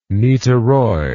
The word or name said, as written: niteroi